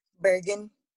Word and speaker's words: Bergen: Um, is a patient care coordinator for Amerisource Virgin. Is that how you pronounce it? Bergen.